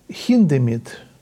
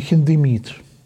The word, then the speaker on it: Хиндемит: Пауль Хиндемит, мы привыкли в России к ударению «Хиндемит», а немцы говорят «Хиндемит».